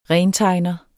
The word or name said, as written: rentegner